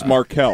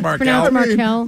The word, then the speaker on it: Markle: And it's Markel. – Come on. – Yeah, it's pronounced Markel.